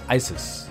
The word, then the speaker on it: Issus: The next great confrontation between the two sides took place in 333 BC near the town of Issus, also sometimes pronounced Isis, today in southwestern Turkey near the city of Iskenderun.